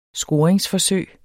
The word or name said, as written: scoring